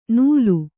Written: ನೂಲು

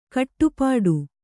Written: ಖಟಿಪಿಟಿ

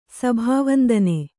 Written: ಸಭಾವಂದನೆ

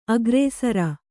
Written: ಅಗ್ರಸರ

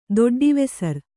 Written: ದೊಡ್ಡಿವೆಸರ್

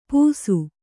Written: ಪೂಸು

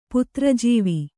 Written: ಪುತ್ರಜೀವಿ

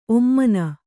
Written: ಔಮೀನ

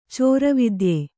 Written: ಚೋರವಿದ್ಯೆ

